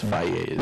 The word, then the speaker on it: Fage: I think it's Fage. – Yeah, how do you say that? – I only know that because of the commercials. – Okay. How do you say it? – Fage, I believe, Fage. Is how it's, yeah. – It's F-A-G-E. – Fage. – Yes.